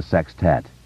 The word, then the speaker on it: sextet: Ritchie had, in fact, been nearly convinced that they wouldn't see Stan again and the Losers Club would become a sextet. A word Ritchie liked a lot, always with the emphasis on the first syllable.